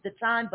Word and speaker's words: Datan: Okay, it's Dayton, but it's spelled D-A-T-A-N like Nancy.